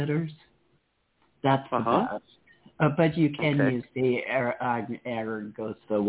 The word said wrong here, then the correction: Angostura: what a fuck. – Angostura, right. – Angostura. – Right. – Always mispronounce that.